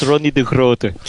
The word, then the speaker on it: Ronnie De Groot: Do I pronounce it like that, De Groot? – Yes, it's more or less like that. It's Ronnie De Groot.